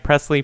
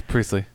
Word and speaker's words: Priestley: Priestley Am I even saying that right? Presley, Priestley? – We'll go. – Which one? You're Priestley. – Nodding. I think it's Priestley. That tells me nothing. – It's Priestley.